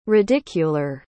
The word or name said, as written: radicular